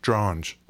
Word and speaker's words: Dronge: Dronge? Rhymes with orange? – Dronge.